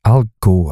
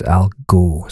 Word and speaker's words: Al Geaux: Al Go, I think he calls it, but it's spelled A L and it's G A A G A A U X. Al Go. So it sounds a bit like Al Gore, but I, th- I think he's actually playing with that.